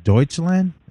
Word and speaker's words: Deutschland: And this is it Deutschland?